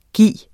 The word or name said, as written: gis